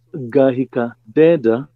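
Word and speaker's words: Ngahika Ndeenda: Yeah, so Gahika Deda, that's the proper way of pronouncing it. – Gahika Deda. – Yeah. Gahika Deda, the N tend to be silent in Gikuyu, was remarkably produced by director Stuart Nash, who is a British producer based in Kenya.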